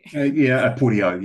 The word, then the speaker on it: Aporto: So today, our special guest on the show is from Aporto. Is that the correct way to say the company? – Aporto. Yes, yeah, okay, hard tea rather than a soft tea.